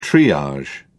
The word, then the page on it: triage: British English